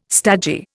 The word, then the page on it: stagey: Indian